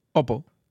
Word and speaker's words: Oppo: Oppo.